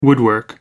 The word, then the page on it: woodwork: American English